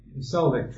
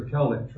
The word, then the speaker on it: Celtic: So the merchants of Tarshish, that's westernmost Europe, the Celtic tribes or Celtic tribes, pronounced either way you like, Britain, France, Spain, Portugal, and so forth, with all the young lions thereof.